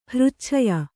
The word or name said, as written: ಹೃಚ್ಛಯ್ಯ